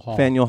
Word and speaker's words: Faneuil: How is it pronounced? – Faneuil Hall.